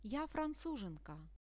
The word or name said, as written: ja francuzhenka